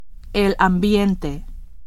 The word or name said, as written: ambiente